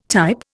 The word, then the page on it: type: Indian